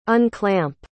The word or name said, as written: unclamp